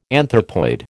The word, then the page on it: anthropoid: American English